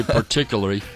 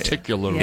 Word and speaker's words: particularly: Some say particularly. It's particularly.